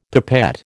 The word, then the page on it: pipette: American English